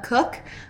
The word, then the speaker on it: Koch: So my name is Laura Cook. It's kind of weird because you'd think it's spelled C-O-O-K, but it's K-O-C-H, random fact there.